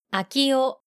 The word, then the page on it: Akio: japanische